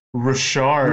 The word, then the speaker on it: Richard: Oh man, wait, I have to correct you, because he's gonna, it's Bruce Richard. – Richard, okay. – Yeah. – Sorry, I'm American. Is he French Canadian? – Yeah, he's you pronounce it just like Maurice the Rocket Richard.